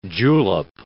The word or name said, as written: julep